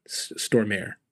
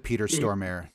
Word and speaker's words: Stormare: I thought I looked it up and you didn't pronounce the T. I thought the T was silent. – Well, maybe. I don't know. But whatever you said the first time was still incorrect. – I've always heard Peter Stormare. Like if we're saying it incorrectly, maybe. But that's how I've heard other people say it.